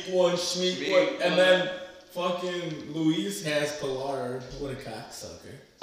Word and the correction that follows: Pollard: I believe it's pronounced Pollard.